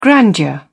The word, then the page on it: grandeur: British English